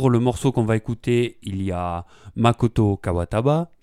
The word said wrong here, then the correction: Kawabata: j'espère que je prononce bien. – C'est Makoto Kawabata en fait.